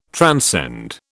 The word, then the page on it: transcend: British English